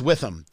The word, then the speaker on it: Witham: She is the Indiana Department Commander of Veterans of Foreign Wars, also a registered nurse at Whitham Hospital in Lebanon. I think I pronounced it wrong. I think it's Witham, W-I-T-H-A-M. It's Witham, correct? – That is correct, Witham.